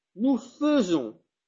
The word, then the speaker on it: faisons: Vous entendez que vous dites feu? – Oui. – Zon. Si moi j'entends ce que vous dites, j'écrirai ça. Feu, zon. N'est-ce pas? Moi aussi je dis nous faisons. Or la prononciation est, à l'écrit c'est AI. Or ce qu'on entend, comme nous l'entendons, c'est E.